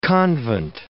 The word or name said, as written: convent